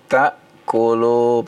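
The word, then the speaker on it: ta'kulu (تأكلوا): An ta'kulu pakai hamzah ya, bukan pakai ain ya. – Wala ala anfusikum an ta'kulu min buyutikum au buyuti abaikum.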